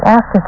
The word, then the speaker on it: ASCII: You may have heard the term ASCII, pronounced ASCII, from computer users.